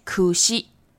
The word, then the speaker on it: 櫛: 这些东西都不能送哦，因为苦西它的日文发音呢有苦跟西的发音，苦就是苦，痛苦的苦，西就是死亡的死，所以这两个发音粘在一起的。